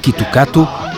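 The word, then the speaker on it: Kitukato (キットカット): Em japonês, a pronúncia do Kit Kat, Kitukato, é idêntica e confunde-se facilmente com a frase Kitukatsu, que significa vais ter sucesso.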